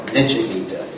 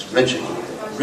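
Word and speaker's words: nritya-gita: Incidentally, most devotees pronounce it Nichagita. It's Richagita. Richa means dancer.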